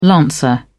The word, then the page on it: lancer: British English